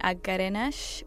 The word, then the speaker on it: Agerenesh: And you can follow me on Twitter at Agarenesh, which is my full first name, and that is spelled AGERENESH.